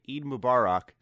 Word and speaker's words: Eid Mubarak: So I wanted to come back to this part here after a night's rest and make sure I fixed my pronunciation and say Eid Mubarak to anyone in our audience who is celebrating. Sorry again for completely butchering how I said it originally.